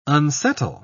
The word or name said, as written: unsettle